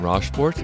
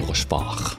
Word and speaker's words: Rochefort: My name is Alan Rochefort, in French it's Alain Rochefort.